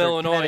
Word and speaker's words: Illinois: And right, I'm from Mr. Illinois, and you don't pronounce the S, education. Right? You don't pronounce the S in Illinois, so.